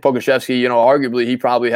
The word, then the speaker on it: Pokuševski: For the Charlotte Hornets, we're going to go with a surprise pick here, and we're going to go with Alexei Pokuševski. – Oh, and you said it right, too.